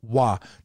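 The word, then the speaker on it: wa (は): When the topic marker in Japanese is written, it's written with this ha, but it's pronounced wa.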